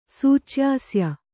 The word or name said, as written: ಸೂಚ್ಯಾಸ್ಯ